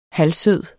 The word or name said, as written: halvsød